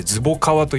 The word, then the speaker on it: 最低女子: と思ったらその次の特集が最低女子の特集でして、これ最低女子と書いてズボカワと読むんだそうなんですけども何、はい、ですかその強敵と書いて友と読むみたいな。